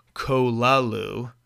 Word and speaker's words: Cthulhu: Lovecraft, and Lovecraft did pronounce it Cthulhu. A lot of people pronounce it a different way, but that's how it's pronounced.